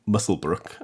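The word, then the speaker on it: Muswellbrook: Because I don't think Musselbrook, as they pronounce it in the town, so say someone on Genius.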